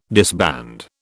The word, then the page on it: disband: British English